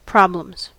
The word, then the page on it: problems: /ˈpɹɑb.ləmz/; /ˈpɹɒb.ləmz/